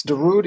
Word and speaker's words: Darude: Ladies and gentlemen, the man behind Sandstorm, Darude. Am I saying it right? – Yes, Darude is very good. Yeah, it comes from the rude, da rude. And like you can see, I'm the rudest.